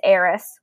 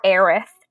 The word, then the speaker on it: Aerith: The main character's name is Cloud, and he discovers this flower girl in a church, and her name's Aerith, or Aerith, depending on how you want to pronounce it.